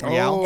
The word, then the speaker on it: Real: I believe it's Real. – Is it Real? – Oh. It's Real. – That's Real.